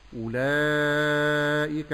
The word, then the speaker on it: أولئك: الهمزة في كلمة أولئك مرققة فأقول أو، ونلاحظ هنا أن هناك واوا بعد الهمزة عليها صفر مستدير، فهذه لا تنطق.